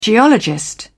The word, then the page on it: geologist: British English